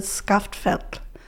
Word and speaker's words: Skaftfell: but I would pronounce it Skaftfell.